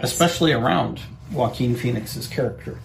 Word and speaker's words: Joaquin: And then when I remembered Joaquin, I couldn't remember how to pronounce it. – Okay. – Joaquin.